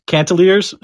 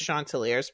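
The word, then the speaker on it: Chanticleers: Or perhaps it's pronounced Cantaliers. – I don't think so. I think it's Chanteliers, because I happen to have in my hot little hands here a YouTube video on how you pronounce it.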